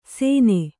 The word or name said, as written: ಸೇನೆ